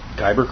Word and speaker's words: Kyber: Now, how is it pronounced? – Kyber Crystal. – Kyber Crystal, okay.